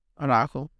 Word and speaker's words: Araujo: As if things are going right now, I would start Araujo.